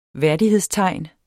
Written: værdighedstegn

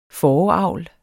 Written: fåreavl

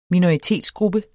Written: minoritetsgruppe

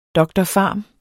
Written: dr.pharm.

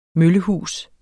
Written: møllehus

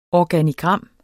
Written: ORGANOGRAM

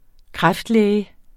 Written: kræftlæge

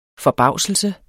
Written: FORBAVSELSEN

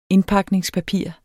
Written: indpakningspapir

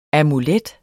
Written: amulet

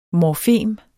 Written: morfem

